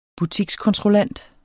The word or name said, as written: butikskontrollant